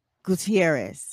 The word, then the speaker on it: Gutierrez: Her name is Miss Karla Gutierrez.